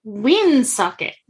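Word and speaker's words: Woonsocket: I'm like, I know, and I'm so close to going to Woon Socket. It's pronounced Woon Socket.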